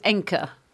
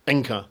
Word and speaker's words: Encke: Look, it's not my Swedish area of expertise, but I would go with Encke. – Encke.